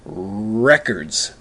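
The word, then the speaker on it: RRRecords: that the name of the record store is spelled R-R-R-E. C-O-R-D, and it's pronounced records.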